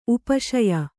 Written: ಉಪಶಾಯ

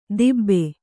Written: ದಿಬ್ಬೆ

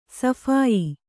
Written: ಸಫಾಯಿ

